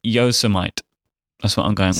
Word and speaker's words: Yosemite: which I understand it's pronounced Yosemite. Is that correct, guys? – Yeah, Yosemite.